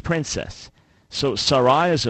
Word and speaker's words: Sarai: All right, so the correct pronunciation is Sarai. Sarai.